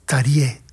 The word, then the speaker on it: Terje: Casualmente lo he mirado en internet, que le hacen una entrevista y también le preguntan cómo se pronuncia Terje. Es Tarje, Todd Terje.